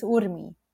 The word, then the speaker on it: Urmi: It's Urmi.